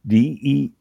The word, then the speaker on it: demi-muid: D-E-M-I-M-U-I-D.